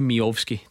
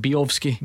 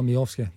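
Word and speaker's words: Miovski: I wasn't really convinced by either of you with your pronunciation, but I'll give you Miovsky. Did you call him Biovsky? – No, Miovsky.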